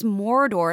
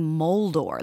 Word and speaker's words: Mordor: Whenever he says Mordor, it always sounds like he's saying Moldor. Like he's adding an L.